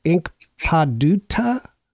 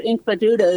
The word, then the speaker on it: Inkpaduta: So I noticed that there's a historical marker about 2 miles north of Smithville, and it's called, and I may pronounce this incorrectly, Inkpaduta? – I'm not sure I give it the correct pronunciation either. Inkpaduta is how I would pronounce it.